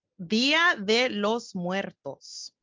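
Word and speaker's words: Día de los Muertos: Dia de los Muertos.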